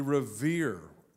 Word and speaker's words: revere: We revere. Revere rhymes with fear. Think of it more reverent. It's revere, not fear.